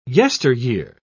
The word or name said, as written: yesteryear